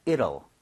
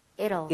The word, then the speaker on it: It'll: 이 T 사운드가 약간 flap이 나요. 그러니까 늘 비슷하게 발음이 돼요. – 네. – 그래서 저는 It will 이렇게 하는 것보다는 It'll. – It'll. – It'll be over. 이런 식으로 이럴 수가 하는 것처럼 이렇게 부드럽게 읽어달라는 거예요.